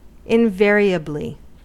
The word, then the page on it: invariably: American English